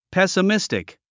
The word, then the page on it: pessimistic: pèsəmístik; ペスミスティク